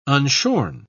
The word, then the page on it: unshorn: British English